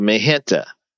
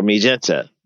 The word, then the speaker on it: MiGente: And so African American people, and then next came something called Magenta or Magenta, depending on how, how you pronounce it. M I G E N T E.